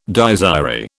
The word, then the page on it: Dies irae: British English